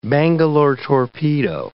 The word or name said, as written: Bangalore torpedo